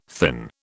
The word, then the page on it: thin: British English